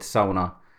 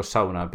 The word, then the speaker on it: sauna: And just for the pronunciation, because I come from Finland and we call it sauna, then I hope you accept my pronunciation for sauna, because that's what we call it.